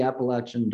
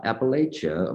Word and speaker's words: Appalachia: It's not Appalachia, of course, it's Appalachia. So it's the Appalachian Dulcimer Museum and Appalachia cider. So just imagine an apple flying at you, you know, so that's your little mnemonic device there.